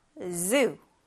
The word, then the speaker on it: zoo: zoo zoo zoo 네, 이 단어야말로 이 Z 발음이 굉장히 돋보이는 그런 단어예요. 그래서 zoo, 이 Z 발음을 유의하면서 발음해주세요.